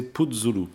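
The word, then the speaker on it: Putzulu: Alors, pour lui faire plaisir, je ne sais pas s'il entendra l'émission, mais pour faire plaisir à Bruno, je précise qu'on devrait prononcer Puzzulu.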